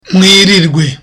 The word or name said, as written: mwirirwe